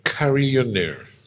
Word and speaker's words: carillonneur: How do you actually pronounce this word? – Carillonneur. – Is it a French word? – It's a French word.